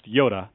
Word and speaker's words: iota: It's actually pronounced Yoda, like the little green dude with the lightsaber.